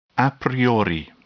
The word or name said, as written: a priori